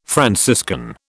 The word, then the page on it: Franciscan: British English